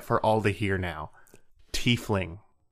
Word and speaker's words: tiefling: Evans wrote this series of novels about a tiefling, because apparently Johannes is going to poop himself if we pronounce it the other way.